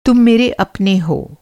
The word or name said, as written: मेरा